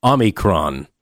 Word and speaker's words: Omicron: It's actually Omicron, I believe is the official pronunciation, but we call it the Unicron variant.